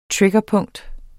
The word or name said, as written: triggerpunkt